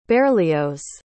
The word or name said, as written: berlioz